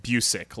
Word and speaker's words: Busiek: it's pronounced Busiek, like music.